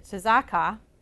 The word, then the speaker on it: tzedakah: In Hebrew, it is tzedakah. I asked one of my Messianic Jewish friends, and she said that's the way you pronounce it.